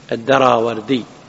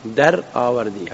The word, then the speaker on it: الدراوردي: يقول قتيبة قال حدثنا عبد العزيز يعني ابن محمد، وعبد العزيز هو ابن محمد الدراوردي، دراوردي هكذا تنطق بالفارسية، لكن العرب كما تعلمون يعربون الكلمات، ها ما يقولون دراوردي، يقولون دراوردي، دراوردي فتح الراء، وإلا فهي كلمة فارسية دراورد.